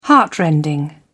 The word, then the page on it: heartrending: British English